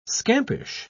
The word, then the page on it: scampish: British English